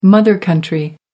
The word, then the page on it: mother country: American English